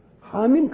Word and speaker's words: حم: حاميم كانت مرت قبله إنما حكت عين سين قاف ما جاتش. فعرفنا حاميم تنطق كده زي ياسين وزي طه وايه وايه حاوميم.